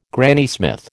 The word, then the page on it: Granny Smith: American English